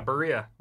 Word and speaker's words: birria: Yeah, that's what people turn to me for, is Latin flavor, which is why how I will say birria. Gotta roll that R there. – Yeah, birria.